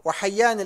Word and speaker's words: حيان: نعم وحيان الأسدي بالياء المشددة المثنات من تحت وحنان بالنون الخفيفة الأسدي والله أعلم.